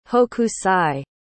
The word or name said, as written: hokusai